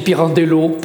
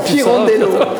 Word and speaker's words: Pirandello: Est-ce que je prononce comme il faut euh, Tout à fait. Aux éditions Icalan. – Moi-même, je dis Pirandello. – Alors, Pirandello, allons-y.